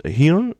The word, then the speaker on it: Hearne: Der ist von Kevin Hearn, ich glaube, das spreche ich richtig aus, wird geschrieben H-E-A-R-N-E, also verbessert mich, weil ich es falsch ausgesprochen habe.